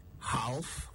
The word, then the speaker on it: half: H-A-L-F. Did you say half? Well, it's actually half. The L is silent. The same rule applies to the plural, halves, and the verb, halve. All of them are pronounced with a silent L. Half, not half. Half. It comes from an old Gothic word, hab, spelled H-A-L-B, also pronounced with a silent L.